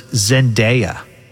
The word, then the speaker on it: Zendaya: So they asked these people in this YouGov poll, and the number one celebrity was Zendaya.